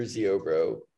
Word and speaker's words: Ziobro: My name is Parker Ziobro.